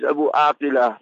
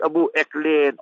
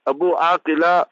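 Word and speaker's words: Abu Akleh: The Israeli Defense Force, last year, they murdered, premeditated murder it was, against the Al Jazeera journalist, Shiriz Abu Aqila, not Abu Eklein, all that. It is a kuffar mentality. We must learn to pronounce the words properly. Abu Aqila, and that was her father, and Shirin was her name.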